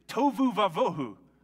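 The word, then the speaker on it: tohu va-vohu: In Hebrew, it rhymes. The Hebrew for formless and empty is tovu vavohu. Isn't that you say that one out loud? Tovu vavohu?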